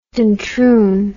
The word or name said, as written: Duntroon